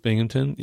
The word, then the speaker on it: Binghamton: Binghamton.